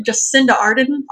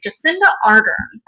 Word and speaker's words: Jacinda Ardern: New Zealand's Prime Minister, Jacinda Ardern. Yeah, I think that's how you pronounce the name. Jacinda Ardern.